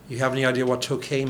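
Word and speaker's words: Toquet: So how do you say Tokay? – Tokay. – And it's T-O-Q-U-E-T.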